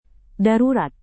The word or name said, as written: darurat